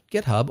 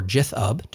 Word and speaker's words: GitHub: I pull up the InfluxDB open source repository on GitHub, or Jithub, depending upon pronunciation choice.